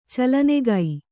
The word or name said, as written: ಚಲನೆಗೈ